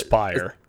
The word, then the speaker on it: Aspyr: It's being done by Asper. Am I saying that right? – I think it's Aspyr.